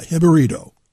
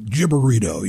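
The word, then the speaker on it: Jibarito: Yeah, Hibberito. I didn't want to say like gibberito, you know what I mean?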